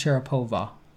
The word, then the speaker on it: Sharapova: It's pronounced Sharapova. Wow, that's so evil that you would say over.